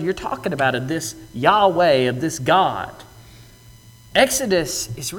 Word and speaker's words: Yahweh: That is specifically the proper name of God, Yahweh, as we generally pronounce it, YHWH.